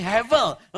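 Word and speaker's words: Hevel (הבל): Jangan emosi, ini bahasa Ibrani, tulisannya Hebel tapi bacanya bukan B tapi V. Jadi kalau dalam tulisan Ibrani, kalau ada huruf B, bet namanya, kalau di tengahnya tidak ada titik, dibacanya V. Jadi bacanya Hevel, Hevel.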